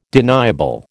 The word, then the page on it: deniable: American English